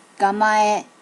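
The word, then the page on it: gamae: native Japanese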